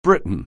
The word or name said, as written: Briton